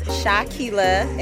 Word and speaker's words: Shakiyla: Hello, my name is Shakila, and it is spelled S-H-A-K-I-Y-L-A.